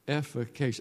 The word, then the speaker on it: efficacious: That's a efficacious. That's a four-syllable word.